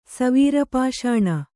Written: ಸವೀರಪಾಷಾಣ

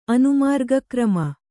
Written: ಅನುಮಾರ್ಗಕ್ರಮ

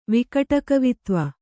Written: ವಿಕಟಕವಿತ್ವ

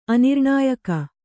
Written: ಅನಿರ್ಣಾಯಕ

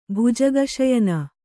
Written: ಭುಜಗಶಯನ

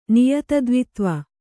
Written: ನಿಯತದ್ವಿತ್ವ

